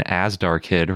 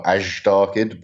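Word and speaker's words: Azhdarchid: So since you have this new pterosaur, it's an Asdarchid, right? Is that how you say it? – Yep. Some people emphasize the z a bit, so more Asdarchid, but it's fine.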